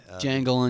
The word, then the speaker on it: Django: Jangle Unchained. – The D is silent.